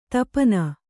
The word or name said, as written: ತುಪ್ಪೆನ್